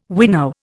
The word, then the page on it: wino: Indian